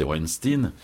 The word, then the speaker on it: Weinstein: Comment vous réagissez à l'affaire de cette année 2017, c'est-à-dire l'affaire Weinstein, je ne sais pas comment il faut prononcer, mais enfin, vous savez de quoi je veux parler? – Oui, bien sûr, oui, c'est Weinstein, et cette affaire-là, oui, elle a embrasé un peu toute la planète.